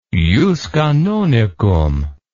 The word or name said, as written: jus canonicum